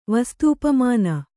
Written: ವಸ್ತೂಪಮಾನ

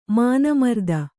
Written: ಮಾನಮರ್ದನ

